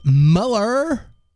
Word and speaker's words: Mueller: It's pronounced Mueller. Like Mueller Light, duh.